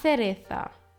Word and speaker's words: Cereza: Die Kirsche, La Cereza. Das C wird als Z ausgesprochen und nicht als S.